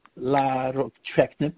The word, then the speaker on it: larotrectinib: The one is called Vitrakvi or larotrectinib, I is pronounced.